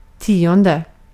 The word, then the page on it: tionde: /ˈtiːˌɔndɛ/